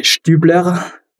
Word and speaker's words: Stubler: It's a German name, so I would say Stubler.